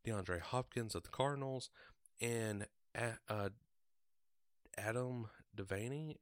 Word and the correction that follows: Davante: if I'm pronouncing that right. Davante, yeah, Davante Adams.